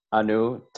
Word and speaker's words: Annu: Uh, so today's guest is Annu Talreja.